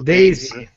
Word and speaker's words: DayZ: come lo pronunciate voi quello? – Daisy. – Daisy. Daisy, DayZ, a seconda se lo vogliamo dire all'americano, la...